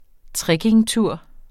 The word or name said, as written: trekkingtur